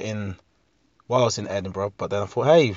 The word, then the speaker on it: Edinburgh: Edinburgh. One of those. There's another one of those towns, places, words in English that isn't pronounced how it's spelt.